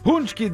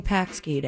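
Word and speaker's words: pączki: Punchki day. – Punchki day. – Which is nothing like what it looks like. It's P-A-C-K-S, or P-A-C-S-K-I. – I was saying Paxki day.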